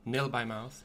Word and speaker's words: Nil by Mouth: Det sånn det uttales. Nill? Nill Nil by Mouth, ja. Nil ja by mouth.